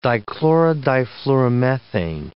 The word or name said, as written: dichlorodifluoromethane